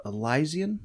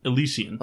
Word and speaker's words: Elysian: I have the Elysian. Is that how you say it? – Nope. – How do you pronounce it? – Elysian. – Elysian. I was close.